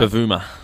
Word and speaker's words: Bavuma: Bavuma.